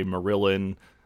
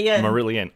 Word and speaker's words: Marillion: The second series ends with the totally heartbreaking Kaylee by Marillion. – Marillion. – Oh my God, I'm sorry. – I've seen them 46 times in concert, I believe. So I know how to pronounce the name of the band.